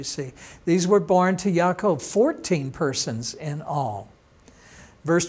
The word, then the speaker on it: Yaakov: Jacob is pronounced Yaakov.